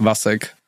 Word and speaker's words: Vacek: My like official legal first name is Václav, but everyone pronounces it as Václav, which I hate. So it's just Vacek.